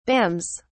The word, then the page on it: bams: British English